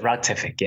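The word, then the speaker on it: Routific: Is it Rotific? – We pronounce it Rotific, yes. – Right, perfect. So I got it right.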